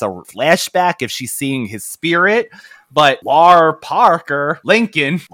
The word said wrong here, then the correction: Lar Park Lincoln: Lara? I hope I'm saying this right. She's reprising. Oh God, he doesn't know another name. He's messing up another name. – Lar Park Lincoln.